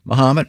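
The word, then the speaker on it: Momence: Muhammad, as in Ali.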